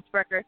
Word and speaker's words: Spreaker: You can find our podcast on iTunes, SoundCloud, Google Play, Stitcher, Spreaker. Mm-hmm. So I always say it wrong, Spreaker.